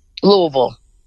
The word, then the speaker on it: Louisville: Is it kind of a two-syllable? It's a two-syllable pronunciation. Louisville? – Louisville. One syllable.